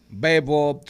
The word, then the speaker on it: bebop: Anduvo por muchos ritmos, jazz, cool jazz, bebop, swing, gospel. Ella Fitzgerald, posiblemente la cantante más grande que, ha, dado Estados Unidos. El bebop, el bebop. Eh, ¿Cómo se pronuncia eso, señor Mariotti? Bebop. – Así, mismo.